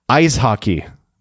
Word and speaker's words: Eishockey: So today's German word is Eishockey, pronounced just like in English, ice hockey, but E-I-S hockey, all one word.